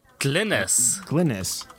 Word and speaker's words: Glynnis: Glynnis. – There's no I in the middle.